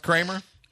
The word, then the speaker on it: Creamer: It's spelled, no, it's spelled Creamer. C-R-E-A-M-E-R. It is spelled Creamer, but it's pronounced Kramer? – Yep.